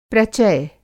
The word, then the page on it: प्रचय: Hindi